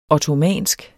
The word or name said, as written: ottomansk